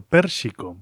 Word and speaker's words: Pérsico: Bueno, en realidad el propio artista nos da la clave de cómo pronunciarlo. Pérsico, vale, pues Pérsico, así se queda. En realidad es la combinación de los nombres de Persia y México por este artista Mehdi Mostak que reside en esas queridas tierras mexicanas por donde sale al aire Mundofonías en numerosas emisoras.